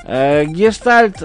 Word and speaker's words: гештальт: Гештальт, ударение на А.